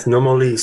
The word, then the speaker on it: Nomoli: But we're going to be talking about the stones, which are the Nomoli. Is that how you pronounce it? Nomoli stones? – Yes, Nomolee Stones.